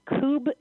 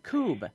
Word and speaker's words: kubb: National Coob Championship. – Coob. – Coob. That's spelled K-U-B-B. Coob. – So it looks like cub, but it's coob.